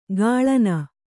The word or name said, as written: ಕಳನಿ